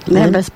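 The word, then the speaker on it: lembas: Okay, so how do you pronounce it? – Lambeth's bread.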